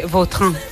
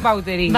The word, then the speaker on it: Vautrin: Pues, es que si me pregunta exactamente cómo se pronuncia, pues así. Claro, pero sí. Yo ya soy Vautrin. – Ya. – Está españolizado total.